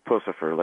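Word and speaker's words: Puscifer: It's Pussifer, like Puss in Boots. – Like okay. Puss in Boots. It is Pussifer.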